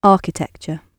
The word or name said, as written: architecture